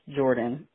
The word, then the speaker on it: Jordaun: This is the name that you butchered on the show. J-O-R-D-A-U-N. It's Jordan. It's... my mom just put an extra letter in there to make it seem like it's a girl's name.